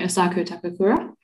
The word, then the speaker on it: Asako Takakura: I'm interested in how, what's the vibe around, I'm going to butcher her name, I'm sorry, Asako Takakura. – Oh, that's perfect. No, you nailed it.